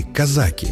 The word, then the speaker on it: казаки: Зато известно точно, что в названии знаменитой повести Льва Толстого ударение падает на второй слог – «казаки».